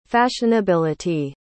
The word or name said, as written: fashionability